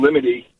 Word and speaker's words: limine: L-I-M-I-T-Y, like limity? – No, it's L-I-M-I-N-E. – Limine.